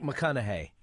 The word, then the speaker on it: McConaughey: Is that how you pronounce his name? Matthew McConaughey? – McConaughey, yeah.